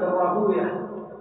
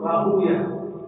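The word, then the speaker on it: راهويه: وقد خالف الإمام أحمد في ذلك صاحبه إسحاق بن راهوية، المحدثون يقولون راهوية مثقوية سيبوية ويغضبون إذا قرأها ويه مثقة ويه لا يريد هكذا ينطقها المحدث راهوية هذه فاعلة.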